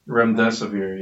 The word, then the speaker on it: Remdesivir: Is it Remdesivir? – Remdesivir, yes.